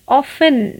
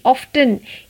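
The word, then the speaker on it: often: ऑफन ना कि ऑफ्टन ये वर्ड भी लोग अक्सर गलत प्रोनाउंस करते हैं it's ऑफन ऑफन O F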